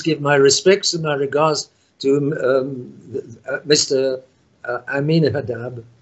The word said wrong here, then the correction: Ahmadinejad: I'm sorry, I can't get the name right. – Ahmadinejad, I will.